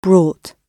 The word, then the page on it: brought: British English